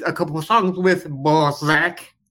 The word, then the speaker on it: Balzac: That's the pronunciation of the band's name is Balzac, B-A-L-Z-A-C. – With a Z. – Yeah, Z. – Balzac.